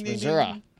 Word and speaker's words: Missouri: No, if you live in Missouri, how do you pronounce Missouri? – Missouri, that's correct. – Yes. – That is correct. It's Missouri.